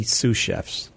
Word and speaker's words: sous: Well, maybe sous chefs, but really only one chef. But, no. – Okay, so I don't even know if I said that right. – Sous, no, you pronounce it correctly.